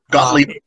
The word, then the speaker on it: Gottlieb: Gottlieb. – Gottlieb. – Gottlieb? Gottlieb. German. – That's how you say it in German? – David Gottlieb Yuengling.